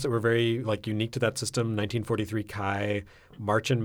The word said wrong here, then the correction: Märchen: Maze. I probably mispronounced that. – Märchen Maze. Okay. I am not German.